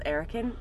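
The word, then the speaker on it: Erokan: It's E-R-O-K-A-N. No one in my life has ever said it right. You did just say it right. – I did say it right? – But, like, okay, good. – When you're reading it, you wouldn't say, oh, that looks like Erican. People would say Arakan or Arokan or whatever.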